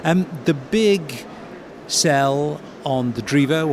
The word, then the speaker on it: Drivo: Americans will say Drivo. Being Italian, I pronounce as E, so it's Drivo.